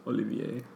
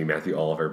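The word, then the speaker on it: Mathieu Olivier: Olivier. – I think it might just be Matthew Oliver, but it's spelled very French. The Matthew is M-A-T-H-I-E-U.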